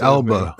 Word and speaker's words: Elba: Like actors today, like Idris Albus, or Albus, how do you say Elba. Abel was Idris I, Alba. I saw Elba.